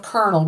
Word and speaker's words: colonel: When the word came to English from the French, the spelling of colonel was adopted by both French and English. But the French changed the pronunciation to include the L, but English speakers kept the R.